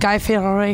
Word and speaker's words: Fieri: Now tonight it's Stagecoach, and you're going to be cooking with Guy Fieri.